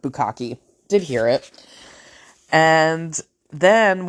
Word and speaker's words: bukkake: And the first card I give my mother on Easter morning with my kids all around, because we're not really thinking, was book hockey. So book hockey, like hockey game, book hockey, book hockey. It's bukkake.